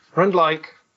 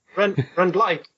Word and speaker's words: Rendlike: Well, I'm a big fan of food and cooking, and Rendlike, or Rendlik in Czech language, means a saucepan. – Rendlik. Okay. Sorry, I'm being terribly British and stressing. – Well, actually, Rendlike is an intended pronunciation. I liked the like at the end.